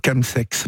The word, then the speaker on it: chemsex: Alors, expliquez-nous ce qu'est d'abord le chemsex, parce que ça s'écrit C-H-E-M-sex, et oui. Chemsex, comment prononce-t-on ? – Chemsex, ouais.